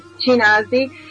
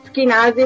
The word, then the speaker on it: Ashkenazi: porque mi nombre se escribe con CH, de hecho me llaman Chinasi, pero es Ashkenazi porque el sonido CH, que en español está producido por QUI, es el mismo, entonces el sonido es el mismo, pero la ortografía es distinta.